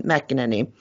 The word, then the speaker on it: McEnany: McEnany.